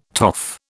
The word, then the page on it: toff: British English